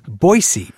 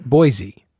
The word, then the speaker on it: Boise: Now, with all these newcomers, one of the things they're going to have to learn is that the proper pronunciation of your city is Boise, right? Not Boise. – That's correct. Boise is the shibboleth. Boise is what people coming in from out of town say.